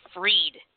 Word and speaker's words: Fried: Now his name is spelled, it's spelled Fried, but it's pronounced Freed.